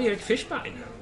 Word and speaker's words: Erik Fischbein: Och så var Erik Fischbein. Säger man så? – Ja, ja, det var korrekt uttalat.